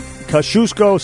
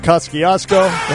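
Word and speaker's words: Kościuszko: No, it was, some people pronounce it Kosciuszko, some people pronounce it Kosciuszko.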